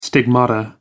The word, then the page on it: stigmata: American English